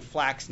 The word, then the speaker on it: flax: We're using flax now, which rhymes with wax.